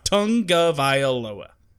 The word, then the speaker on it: Tagovailoa: It is tongue, like the tongue in your mouth. Tungavailoa is how you pronounce that last name.